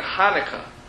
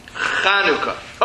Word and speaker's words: Chanukah: But Chanukah, which is unique to this particular language as opposed to English, we now know the correct pronunciation of that holiday, which is namely Chanukah.